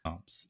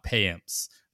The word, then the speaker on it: Payamps: It's Yoel, not Joel. Yoel Pyamps, not Payamps.